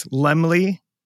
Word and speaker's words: Laemmle: From the top here, so in the year of 1914, during the First World War, a German-American immigrant named Carl Laemmle, remember that, it's Laemmle, Laemmle, it's spelled L-A-E-M-M-L-E, pronounced Laemmle, Carl Laemmle.